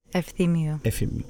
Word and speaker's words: Efthimiou: Today I'm very excited to have Amánda Efthimiou. How do you pronounce it? – Efthimiou.